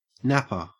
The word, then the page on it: napper: Australian English